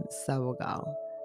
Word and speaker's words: Sabogal: Sabotage. Sounds like my last name, Sabogal.